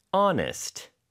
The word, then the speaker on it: honest: 네, to be honest. There's an H hiding at the beginning. H-O-N-E-S-T.